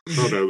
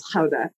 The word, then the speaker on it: Gouda: Gouda with a hard G. – Cupcake chaos. – It's an Americanization. The correct pronunciation, Celia, is Gouda.